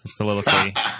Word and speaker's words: Soliloquy: Soliloquy? – Yes. Is that how you pronounce it? – For the non-English majors in the group, yes. Soliloquy.